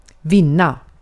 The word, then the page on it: defeat: American English; /dɪˈfiːt/